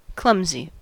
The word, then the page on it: clumsy: American English